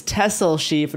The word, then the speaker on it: Texel: It's one of those European places where the pronunciation does not match how it's written. It's Texel sheep, not Texel sheep.